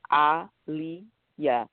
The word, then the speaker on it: Aliyah: So I was telling her, it's Aliyah. And I said, like Aliyah Oxen Free.